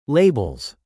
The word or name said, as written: labels